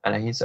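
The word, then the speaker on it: Anaïs: I think it's Anais, but yeah. – Anais, okay.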